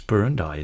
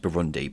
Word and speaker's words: Burundi: It's Burundi, isn't it? – I think it's pronounced Burundi, but you're correct.